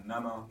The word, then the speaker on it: Nana: So my name is Nana Agyemang, which is November Alpha, too corporate for spelling it all phonetically.